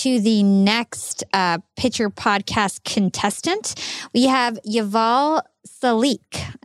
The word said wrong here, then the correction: Yuval Selick: and hopefully I said that correctly. – Yuval Selick, yes. – Yuval Selick. – Selick, yeah, of course. Like Tom Selleck.